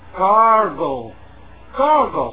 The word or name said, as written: gargle